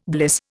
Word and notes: bliss: Indian

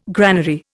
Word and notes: granary: Indian

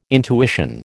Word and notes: intuition: American English

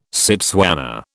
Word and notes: Setswana: British English

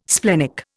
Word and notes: splenic: Indian

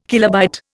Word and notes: kilobyte: Indian

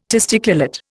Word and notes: testiculate: Indian

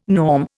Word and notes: norm: Indian

